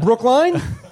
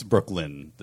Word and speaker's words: Brooklyn: No, it's Brooklyn. That's how they say it. I mean, I know the accent is kind of weird to your ears, but... – No, it's Brookline because it's a Y. – I know it seems like that. And I know your brain does things with Ys and Es and Is. And I know you have a lot of problems with names. It is Brooklyn, though. They say it right in that song.